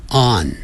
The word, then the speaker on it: on: 要用 on。很多人念成 on，你念成 on 的话就变成 ong 了，有 g 的话才会念成 on，那个鼻音出来，没有 g 啊，所以这个时候呢是 on 啊。